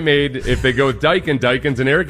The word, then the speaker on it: Daikin: It's Daikin.